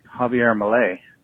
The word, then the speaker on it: Javier Milei: Second up was, how about that Javier Milei? – Oh, yeah.